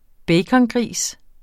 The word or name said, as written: bacongris